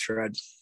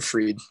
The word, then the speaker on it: Fried: I'll go Max Fried, or Freed, however you want to say it.